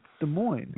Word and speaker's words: Des Moines: Yeah, there's a silent S, and I like to incorporate it. No one's silencing that S. – Des Moines.